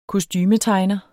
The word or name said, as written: kostumetegner